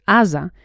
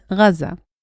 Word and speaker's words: Gaza: In Hebrew, we pronounce it Aza. In Arabic, they pronounce it Gaza.